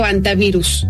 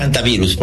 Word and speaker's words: hantavirus: ¿Se pronuncia así, hantavirus o antavirus? – En general se dice hantavirus, porque hantavirus. Así se dice internacionalmente.